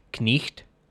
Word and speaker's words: knight: The K was not silent, and G-H represented the sound. What's more, unlike nowadays, the letter I had one established pronunciation. So not only would you pronounce the word phonetically as knicht, but if you wanted to represent that word in writing, you would naturally write it K-N-I-G-H-T.